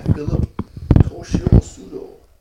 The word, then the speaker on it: Philip Toshio Sudo: And I've got this cool old book that was called Zen Guitar, written by Philip Toshio Sudo.